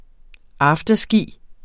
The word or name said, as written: afterski